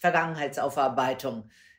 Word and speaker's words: Vergangenheitsaufarbeitung: This is pronounced Vergangenheitsaufarbeitung.